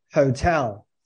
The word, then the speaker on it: hotel: Listen to the way I say hotel. So like hotels, you know, it's a great hotel. It's like almost like the emphasis is on the second part.